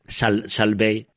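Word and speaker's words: Salt Bae: Saltbay.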